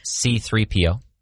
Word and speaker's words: C-3PO: We have 3CPO. I know that one. – Nope. C-3PO.